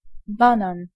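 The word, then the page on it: banan: IPA: [ɛn.baˈnɑːn]; Swedish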